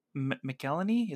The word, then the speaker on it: McElhenney: Ryan Reynolds and Rob McElhenney.